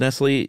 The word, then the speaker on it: Nestlé: I figured, but is it pronounced Nestle in French? – I think so.